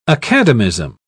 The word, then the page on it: academism: British English